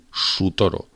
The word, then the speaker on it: Sutoro: Asirios cristianos sería la policía de los asirios cristianos, que son Sutoro, con S empieza la primera sílaba, S-U, Sutoro.